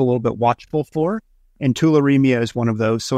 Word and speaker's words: tularemia: Am I pronouncing it right if I say tularemia? – That's it. Yep.